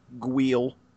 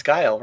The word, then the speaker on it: Guile: Gwiel. – Wait, is that how you say it? – No. – Oh, okay. – That's how I heard people say it back in the day. – It's Guile, right? – Yeah. – Yeah, it's okay. Guile.